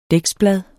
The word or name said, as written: dæksblad